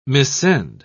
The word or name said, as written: missend